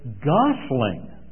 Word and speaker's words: gosling: First of all, do you remember when I embarrassed myself the other day with the story about the gooselings, which many of you corrected me on? And the proper pronunciation is a gosling, I was told.